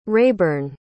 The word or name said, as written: raeburn